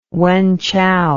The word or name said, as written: Wenchow